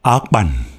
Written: Akpan

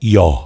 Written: Yaw

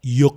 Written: Yok

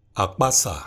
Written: Akpasa

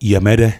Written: Yemede

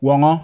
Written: Wawngaw

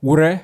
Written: Wure

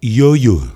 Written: Yoyo

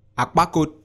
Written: akpakot

